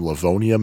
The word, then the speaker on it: Livonia: It was located in the Wonderland Mall in Livonia, Michigan, assuming I'm pronouncing that correctly.